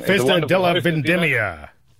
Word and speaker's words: Festa della Vendemmia: Festa della Vendemmia. I'm sorry, I probably said that wrong. – Festa della Vendemmia. – That's it.